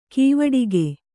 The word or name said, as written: ಕವಡುಗ